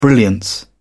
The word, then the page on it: brilliance: British English